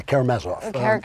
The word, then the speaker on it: Karamazov: Am I saying that right? – Yeah, the Karamazov. – Yes.